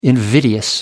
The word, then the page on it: invidious: American English